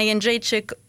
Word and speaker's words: Jędrzejczyk: Joanna Jędrzejczyk versus Karolina Kowalkiewicz. Som ska mötas. – Bra uttal också på efternamnen.